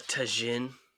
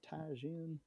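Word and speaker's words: tajín: There's nothing worse than a white guy going, let me get some of that tajin. – Ooh. Micheladas. – You live in Texas. You should know how to pronounce Spanish J's.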